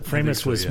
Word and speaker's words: Framus: Framus, most famous, probably the Stones.